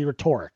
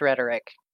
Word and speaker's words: rhetoric: And at the same time, you would be like, you're a fucking writer because you just said fiery rhetoric. – Point of order, it's rhetoric. – Rhetoric, thank you. I read it, never pronounced it.